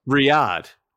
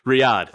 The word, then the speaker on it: Riyadh: Riyadh. – Riyadh. – That's how you pronounce the capital of Saudi Arabia.